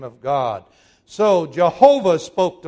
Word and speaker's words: Jehovah: And in Germany first, they began to say Yahweh, but since in German J is a Y, when we took it into English, we began to pronounce it as Jehovah.